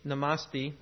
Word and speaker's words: namaste: The Hindu greeting, namaste, I have no idea if I'm saying that right.